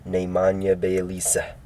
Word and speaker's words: Nemanja Bjelica: That's how you say his name, Nemanja Bjelica.